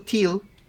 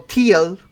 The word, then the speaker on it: teal: Es el famoso teal o teal. No sé cómo se dice. ¿Lo dije bien? – En ambas formas está perfecto.